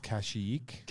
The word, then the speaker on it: Kashyyyk: It's pronounced Kashyyyk.